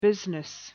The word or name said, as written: business